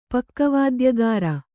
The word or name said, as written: ಪಕ್ಕವಾದ್ಯಗಾರ